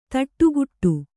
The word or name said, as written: ಥಟ್ಟುಗೆಡು